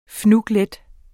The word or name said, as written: fnuglet